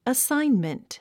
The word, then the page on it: assignment: əsáinmənt; アッサインメント